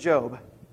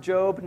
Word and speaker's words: Job: Put a line between Esther and Job. And for those of you who are new to the Bible, it is pronounced Job, not Job.